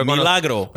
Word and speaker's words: Milagro: Why you pronounce it like that? – I know, right? – Milagro. – Well, it's when I'm talking English, man. I have an English accent. – Pero Milagro.